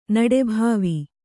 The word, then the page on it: ನಡಬಾವಿ: naḍa bāvi